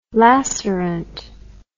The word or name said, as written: lacerant